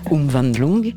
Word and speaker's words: Umwandlung: Voilà, Umwandlung.